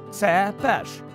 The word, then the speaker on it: Цепеш: Увага! Наголос на перший склад. Цепеш.